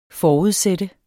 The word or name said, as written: forudsat